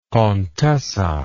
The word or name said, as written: contessa